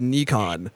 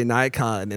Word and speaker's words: Nikon: So that's funny because, you know, the company Nikon is actually pronounced Nikon, but Americans always say Nikon and it always annoys British people.